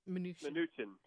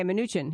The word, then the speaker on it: Mnuchin: Mnuchin. – Okay, Mnuchin.